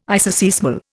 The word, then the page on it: isoseismal: Indian